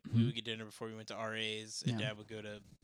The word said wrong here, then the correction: Arby's: I believe it's pronounced Arby's.